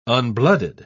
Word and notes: unblooded: British English

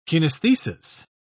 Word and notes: kinaesthesis: British English

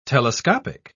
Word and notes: telescopic: British English

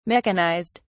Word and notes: mechanized: British English

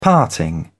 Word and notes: parting: British English

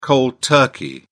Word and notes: cold turkey: British English

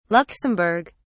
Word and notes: luxemburg: British English

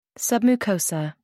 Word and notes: submucosa: American English

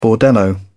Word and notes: bordello: British English

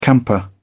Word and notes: camper: British English

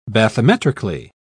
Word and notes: bathymetrically: British English

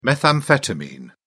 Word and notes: methamphetamine: British English

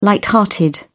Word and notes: light-hearted: British English

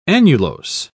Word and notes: annulose: British English

